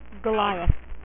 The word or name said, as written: goliath